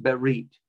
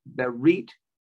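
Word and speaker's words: berit (ברית): The word is pronounced berit. Remember, read from right to left. From right to left, the word is berit.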